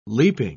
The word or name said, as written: leaping